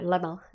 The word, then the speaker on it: Lommel: Yeah, Lommel.